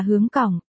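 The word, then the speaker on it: hướng cổng (香港): Tên gọi, Hồng Kông, xuất phát từ tiếng Quảng Đông đọc là hướng cổng,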